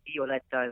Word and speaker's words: Violetta: She's the, of course, my main character, and in the Italian language, the letter I is pronounced E, so Violetta is how that's pronounced.